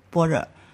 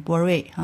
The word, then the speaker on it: 般若: 所以呢，他为了不产生歧义，所以就没有译成这个，没有意义啊，没有译成按意思来译，而是就保留他的发音般若，有的发成般瑞啊。